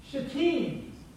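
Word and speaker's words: shittim: This word is pronounced, it's a Hebrew word, and you know, I'm sure you come here to learn Hebrew every week, but it's shatim.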